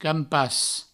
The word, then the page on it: campas: Provençal